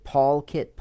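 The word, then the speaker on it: polkit: Did I say it right? PaulKit. Policy. Yeah, PaulKit. Policy Kit is what it means. PaulKit is how it is spelt.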